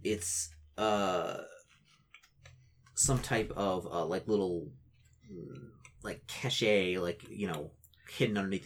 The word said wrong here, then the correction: cache: A cache? – Yeah. You find, Because it's pronounced cache, not cachet.